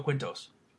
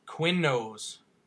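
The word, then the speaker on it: Quiñones: Yeah, it's Joe Quinto or Quinnos. Maybe I said it wrong. There's no T in it. Q U I N O N E S.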